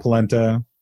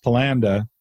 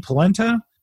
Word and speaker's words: polenta: Sometimes it was on polenta. – Oh, yum. – Which until I was like 18 years old, I thought was pronounced polanda because that's how the family pronounced it.